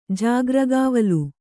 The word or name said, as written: ಜಾಗ್ರಗಾವಲು